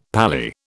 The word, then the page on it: pally: British English